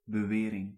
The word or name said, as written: bewering